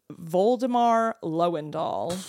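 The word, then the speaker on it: Woldemar Löwendahl: Yeah, well, it's spelled with a W, but I assume that means it's pronounced with a V. Voldemar Lowendahl.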